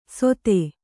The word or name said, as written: ಸೊಥೆ